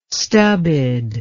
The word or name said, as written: stubbed